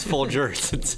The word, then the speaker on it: Folgers: No, I know you're serious, but you said Folgers. – Yeah. – It's Folgers. It's a soft G.